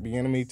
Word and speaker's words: Bieniemy: They have interviewed Eric Bieniemy today.